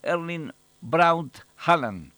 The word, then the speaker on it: Erling Braut Haaland: Con su habitual tono tranquilo, Haaland respondió que fuera de Noruega se le podía llamar como Erling Braut Haaland, tal como suele escucharse en transmisiones internacionales.